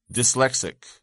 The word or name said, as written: dyslexic